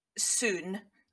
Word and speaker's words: Sundiata: Sundiata Keita. – Yeah, so basically, his first name is the only thing that I was able to find a pronunciation thing for. – Right. – Which is apparently Sun-Ja-Ta.